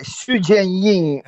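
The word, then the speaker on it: Xu Jiayin: Sì, è Xu Jianjin, però è il suo nome reale, che poi era diciamo cantonese, e anche Hui Kaien, insomma, si legge in maniera diversa, si pronuncia in maniera diversa a seconda delle due lingue, ecco, che si parlano in Cina.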